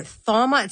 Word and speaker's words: thauma: Thauma, it's T-H-A-U-M-A. – Yeah, it's I'd say it thauma, but, you know, actually, you're allowed to say it however you want.